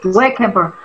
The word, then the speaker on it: Isuekhebor: So my name is pronounced, the I is pronounced as an E. So you start with E. The next word is swear. The next word is care. The next word is ball. So Isuekhebor.